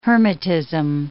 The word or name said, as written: hermetism